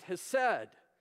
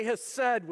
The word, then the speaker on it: hesed: It's a word that's pronounced hesed. Say hesed with me.